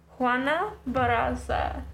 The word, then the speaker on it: Juana Barraza: Can't roll my R's, but it sounded good. – It's enough. – Better. It's better than me and Michelle would have pronounced it. – Try it. – Can I see how it's spelled? Okay. Juana Barraza. – That's pretty good.